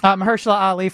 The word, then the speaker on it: Mahershala Ali: Mahershala Ali. – Mahershala Ali for supporting actor, yes.